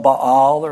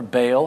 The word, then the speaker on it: Baal: She murdered various servants of the Lord in order to place her own servant, false servants of Baal or Baal, however you like to pronounce it, Baal or Baal, in their place.